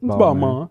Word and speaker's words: Balmain: First of all, I'm not even saying it right. – It's Balmain. – Yeah, there we go.